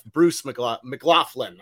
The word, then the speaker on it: Bruce McLaughlin: Bruce McLaughlin.